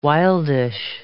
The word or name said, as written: wildish